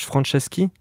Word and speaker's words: Franceschi: Et cette fois, ils sont en featuring avec Josh Franceschi. Non, attends, je le prononce mal. Josh Franceschi, qui est le chanteur de Yumiatix.